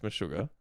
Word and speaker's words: Meshuggah: Did I pronounce that right? – I think it's Meshuggah. – Meshuggah.